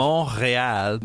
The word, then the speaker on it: Montréal: Montréal. Montreal is an island, and in the middle of the island is a mountain. In English, we call it Mount Royal, and in French, it's Montréal, and that's the whole island takes its name from that.